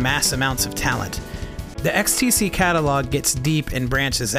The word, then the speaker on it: XTC: Yeah, per the documentary, it's pronounced XTC and not ecstasy.